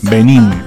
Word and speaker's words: Benin: Benin se dice así, ¿no? Benin es con el acento en la última, en la I final.